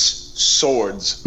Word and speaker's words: Sowards: Lori, my last name is literally, it's Swords, like two of the weapons, right?